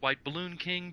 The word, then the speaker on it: WHTBLLNKING: Well, it's supposed to be pronounced White Balloon King, but it's spelled WHTBLLNKING.